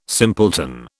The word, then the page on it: simpleton: British English